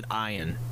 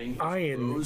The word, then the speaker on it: Ian: He pronounces it Ian. Don't ask me why. – He has pronounced it Ian since like season one of Beverly Hills 90210.